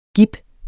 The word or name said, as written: gib